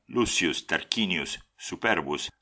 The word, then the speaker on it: Lucius Tarquinius Superbus: Después de acceder al trono, Lucio Tarquinio el Soberbio, que en latín se pronuncia Lucius Tarquinius Superbus,